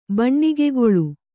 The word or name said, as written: ಬಣ್ಣಿಗೆಗೊಳ್